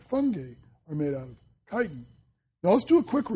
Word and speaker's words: chitin: And the cell walls are made of a material called chitin.